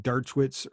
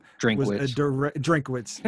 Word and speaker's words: Drinkwitz: Them hiring Dershowitz or however you say his name. – Drinkwitz.